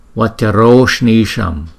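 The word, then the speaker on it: vajroṣṇīṣaṃ: Chữ A mà tiếp theo chữ U là thành chữ O. Chúng ta đọc là Vacharoshnisham. Chữ A với chữ U này cộng lên thành chữ O thì Vacharoshnisham. Chữ N cong lưỡi lại, chữ Y kéo dài hai nhịp, chữ S là cong lưỡi lại.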